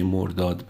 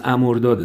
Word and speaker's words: امرداد: به جای واژه مرداد به معنای مرگ از امرداد استفاده کنیم که معنی جاودانگی داره و تلفظ درست همین امرداده